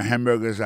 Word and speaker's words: hamburger: Pour ça concerne les prononciations, on prend hamburgers à son mot qui est trois syllabes, je dis hamburger, hamburger, on mange à bon, si mange moi, hamburger à mort. Tu es un HM, bam, tu es hamburger. Si tout le monde a un petit cheese sur lui, petit fromage, dit bon en bouche en pile, it's very tasty. On a remarqué que dans mon hamburger, ça a comme t'as dit, il y a trois syllabes là-dedans. Quand tu appuies et puis plus ça, c'est sous premier syllabe là.